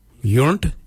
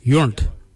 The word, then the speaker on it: yoornd: Mirda is spelled M-I-R-D-A. The next colour is Yunt. Yunt is yellow. Yunt. Yunt is spelled Y-O-O-R-N-D.